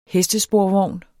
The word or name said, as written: HESTESPORVOGNE